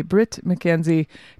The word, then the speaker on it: Bret McKenzie: Bret, I will say it correctly, Bret McKenzie doing his Songs Without Jokes tour.